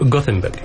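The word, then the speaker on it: Gothenburg: جونسبرغ بالسويد، صح كذا الاسم انا نطقته صح؟ – جوثنبرغ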